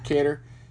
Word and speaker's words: Kattar: But, like, this is going to be an interesting fight, because you said Kattar is how it's pronounced? Kattar? – Yeah, Cater.